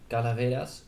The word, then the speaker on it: Calavera: Wacht, ja, La Calavera is Calaveras, Spanish pronounced. Calavera.